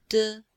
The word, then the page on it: de5: female